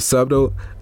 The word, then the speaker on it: subtle: It's a subtle. I never can say that word. How do you say that? – Subtle. – Subtle. – Yeah. – Is that how you say it? The B is silent.